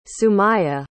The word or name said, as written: sumayah